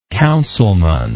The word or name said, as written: councilmanic